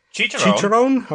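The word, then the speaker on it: chicharrón: Chicharron.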